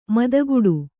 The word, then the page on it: ಮತಿಗಿಡು: matigiḍu